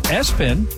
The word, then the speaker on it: ESPN: The word ESPN, pronounced Espen.